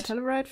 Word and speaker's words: Telluride: Telluride Filmfestival, heißt das so, ja.